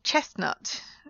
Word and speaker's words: chestnut: Chestnut, you don't pronounce it there.